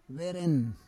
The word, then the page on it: veren: Roon